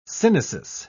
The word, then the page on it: synesis: British English